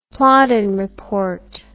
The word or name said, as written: plowden report